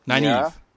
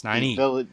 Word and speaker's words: Nynaeve: Nynaeve. – Yes, Nynaeve.